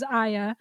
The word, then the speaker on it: Aya: Just stick to my nickname, which was Aya.